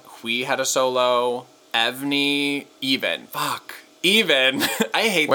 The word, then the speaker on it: EVNNE: Because it's spelled E-V-N-N-E. So I say Evnie. – Okay. – Evnie. No, fuck. Even, formerly Blit.